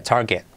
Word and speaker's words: target: Target. Lo mismo, R genérica precedida de A, como car. Target. Y la T última de get. Casi no se pronuncia target, ¿vale?